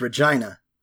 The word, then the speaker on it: Regina: One, the city in Saskatchewan is pronounced Regina. – No, fuck you, it's not. That's... – Yeah, it 100% is.